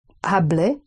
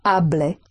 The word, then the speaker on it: hablé: Hablé. – The H is never pronounced. – Sorry. Hablé. Hablé.